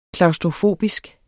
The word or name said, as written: klaustrofobisk